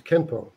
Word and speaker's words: Kenpo: In Japanese, it's pronounced as Kenpo.